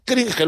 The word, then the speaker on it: cringe: Dije antes cringe, ¿lo dije bien? – Sí, lo dijiste bien, lo dijiste bien.